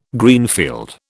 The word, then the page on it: Greenfield: British English